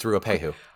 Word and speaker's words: Ruapehu: They are climbing up Mount Doom, which was shot on a volcano called, I should have looked up how to pronounce this, Ruapehu. – I think that's right. I think it's Ruapehu. Yeah.